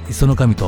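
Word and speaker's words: 石上: 石のね、上と書いて磯の神と呼ぶそうですが、この神社がですね、そうですね、桜門がすごく竜宮城に似てるというか、まあ一遍行かれた方はね大体わかると思うんですけど、本当それはね、浦島太郎に出てくる竜宮城のように見える作りです。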